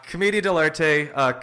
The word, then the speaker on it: Commedia dell'arte: Let's talk about, I'm not sure how to say this properly, Commedia dell'arte. – Wow, yeah, you nailed that on the first try. Commedia dell'arte, commedia for short, means comedy of the artists in Italian, or comedy of the professionals.